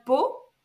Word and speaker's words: Pau: It's called Pau, but it's written PAU, so nothing to, yeah, it's okay.